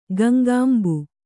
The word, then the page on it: ಗಂಗಾಂಬು: Kannada